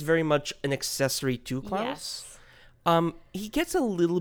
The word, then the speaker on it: Klaus: Just also, how do you say Klaus? – Klaus? Is it Klaus? – Okay, because you've been saying like Klaus. Sorry. It's Klaus.